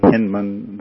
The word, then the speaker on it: Hindman: Woody Hindman, it's spelled H-I-N-D-M-A-N, and most people pronounce it Hindman, the family pronounces it Hindman,